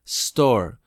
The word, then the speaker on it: store: Y yo, qué feo se escucha store, ¿no? No se dice store, se dice I'm going to the store.